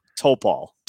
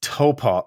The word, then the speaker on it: Topol: Seth Tupal? Close. – oh, Topol. Topol. Oh, I had the wrong emphasis on the wrong syllable.